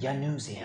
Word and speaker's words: Januzzi: Januzzi? What is it, Captain? – It's Januzzi. – Januzzi. – It's a soft J.